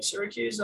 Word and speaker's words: Syracuse: So I was like, Syracuse, am I right?